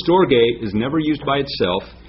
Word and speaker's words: storge: It's storge. You might want to write this down. English is spelled S-T-O-R-G-A-Y, storge.